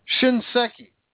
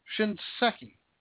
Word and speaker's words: Shinseki: The VA Secretary Eric Shinseki, I can never pronounce his stupid name, Shinseki. Shinseki, that's what it is. Shinseki.